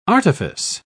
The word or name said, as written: artifice